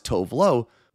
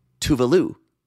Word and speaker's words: Tove Lo: When she made her appearance on RuPaul's drag show, she was introduced not as Tove Lo, but as Tove Lou, which had Twitter in a tizzy. Then a keen Twitter user pointed out that's not the way to pronounce it either and posted a clip of the singer saying her own name, which is apparently pronounced Tuvalu. So from Tove Lo to Tove Lou to Tuvalu. Tuvalu.